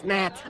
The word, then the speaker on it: gnat: It's a G. Silent. It's gnat.